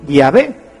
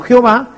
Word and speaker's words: Yahvé: Y por eso nosotros sabemos que la pronunciación correcta de Yahvé es así, Yahvé, y no Jehová,